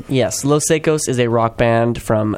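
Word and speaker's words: Los Saicos: I think it's pronounced Los Secos.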